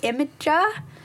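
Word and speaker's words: Imgur: how do you say it? Imager? – Yeah, imager. – Yeah, that's how you say it, isn't it? I always think imager, but it's like, no, that's not right.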